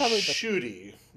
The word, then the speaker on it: Ncuti: It's pronounced Shuti. From a video that I saw, but Shuti Gatwa is how you pronounce the name. – Okay, I never would have guessed considering it's spelled N C U T I.